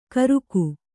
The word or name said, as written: ಕರುಕು